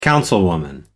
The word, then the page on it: councilwoman: American English